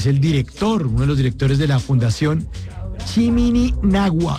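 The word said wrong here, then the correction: Chiminigagua: No, ya lo dije mal otra vez, pero voy a ensayarlo aquí, Chimini. Chimini Gagua. Ahora sí lo dije bien.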